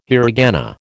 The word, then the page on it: hiragana: American English